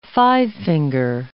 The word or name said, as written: five-finger